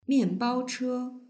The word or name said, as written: mianbaoche